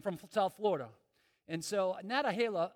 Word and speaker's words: Nantahala: Nantahala, is that how you say it? And Nantahala? Yeah, that place, okay, in North Carolina.